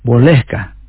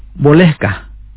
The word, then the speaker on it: Bolehkah: Bolehkah significa puede o puedo. Lo pronunciaré lentamente. Puedes repetir después de mí. Bolehkah.